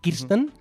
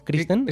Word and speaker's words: Kirsten: Kirsten, que no, Kirsten. – Es verdad. – Cuidado con esto.